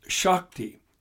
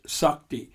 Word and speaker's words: Shakti: And the male aspect is Shiva, which is the god of destruction in Hinduism, and the female aspect is Shakti. Some pronounce it Sakti.